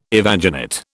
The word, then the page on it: evaginate: British English